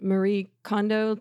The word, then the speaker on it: Marie Kondo: The woman who does, I just saw this so beautifully, little side note, but Marie Kondo, Tidy Up, am I saying her name right, I think? – Yeah.